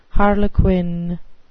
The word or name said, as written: harlequin